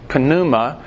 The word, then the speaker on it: pneuma: So now, again, we've missed something in English here, because this word Spirit is the Greek word pneuma. I'm pronouncing the P because that's what our guide in Greece told us we're supposed to do, although I think most Americans say not. We get our word pneumatic from this. It's spelled the same way, P-N-E-U, and that means air-actuated, pneumatic.